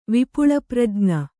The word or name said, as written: ವಿಪುಳಪ್ರಜ್ಞ